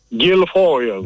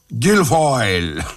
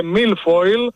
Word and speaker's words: Guilfoyle: Για πείτε μου το όνομα Κυλφόιλ, έχετε μια ειδική έτσι. Προφορά. – Guilfoyle, όπως λέτε κι εσείς. – Guilfoyle.